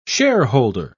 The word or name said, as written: shareholder